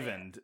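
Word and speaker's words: Eyvind: Ivind, but it's E-Y-V-I-N-D.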